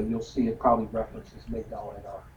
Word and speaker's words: Migdal Edar: Migdal Edar. M-I-G-D-A-L-E-D-A-R. M-I-G-D-A-L-E-D-A-R-E-D-E-R. It's spelled both ways. Migdal Edar. Migdal means tower. Edar means of the flock or sheep. – M-I-G-D-A-L.